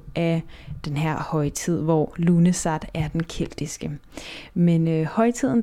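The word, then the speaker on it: Lughnasadh: Ja, det udtales Lunesat, men det staves på en meget forvirrende måde, og ja, du kan se, hvordan det staves her i podcastens titel.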